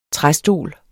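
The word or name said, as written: TRÆSTOL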